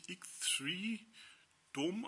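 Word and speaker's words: X3DOM: Als allerletztes haben wir X3D Dom, also X3 Dom, spricht sich X3 Dom aus, also X-Freedom soll, man, soll das bedeuten wohl.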